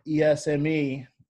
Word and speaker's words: Esme: Um, so her name's Esme, E S M E. Um, it's pronounced Esme, like like the letter S and the month May people say it all different ways, um, but that's you know that's that's her name, um, and her middle name's Beacon.